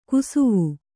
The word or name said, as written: ಕುಸುವು